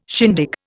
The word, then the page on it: shindig: Indian